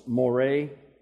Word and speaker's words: more: It's more, M-O-R-E, with an accent on the E, more.